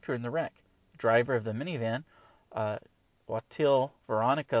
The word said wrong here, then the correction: Xochitl: Lopez, 39, or Xochitl, man, I swear I used to know how to pronounce his name, Xochitl, Veronica Lopez, 39 of Houston, was pronounced deceased, as was a juvenile passenger.